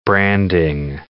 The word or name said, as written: branding